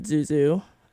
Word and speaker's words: Zuzu: And right before that was Zuzu, or, well, I pronounce it Zuzu. I've heard other people say, like, ZZ, but I don't think that's right.